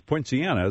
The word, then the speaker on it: Poinciana: Did I, Mark, did I get, it's Poinciana, isn't it? – You did very well.